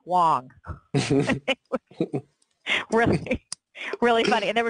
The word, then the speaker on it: Wang: Remember when Chenmin Wang played? Or, I can never say his name correctly. – Wong? – Played for the Nats. Wong. I always say it wrong.